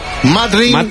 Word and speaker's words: Madrid: Pero, ¿cómo se dice Madrid? Madrid. – Teóricamente, en vez de Madrid, Madrid.